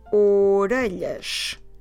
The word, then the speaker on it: orelhas: So the word for ears, or ears, the ears, is orelhas. Okay, it's three syllables. Orelhas. So if you want to say the ears, we go as orelhas.